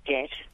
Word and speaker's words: debt: I teach English as a second language, and my students will often ask me why we don't pronounce the B in doubt and debt, mm-hmm.